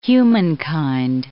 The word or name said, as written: humankind